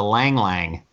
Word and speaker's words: ylang-ylang: I certainly haven't heard of a yang-yang. My research department says the Y is silent. – Oh, so it's a lang-lang.